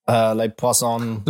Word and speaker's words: Les Poissons: Les Poisons. Oh, what's the French song called? I can't pronounce that. – Les Poissons.